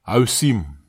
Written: awsim

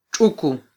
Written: txuku

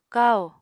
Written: kahò

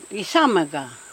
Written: isamega

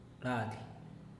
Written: lady

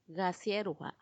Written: gaasierua

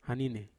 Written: hanine

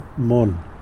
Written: mon

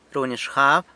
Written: runy xgab

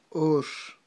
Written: ox

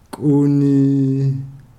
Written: kuni

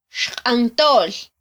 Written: xqantol

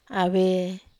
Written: ave

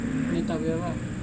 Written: neta veava

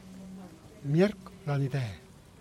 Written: mierc ra ide

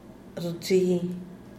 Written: rxhi

